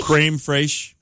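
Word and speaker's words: crème fraîche: Creme fraiche.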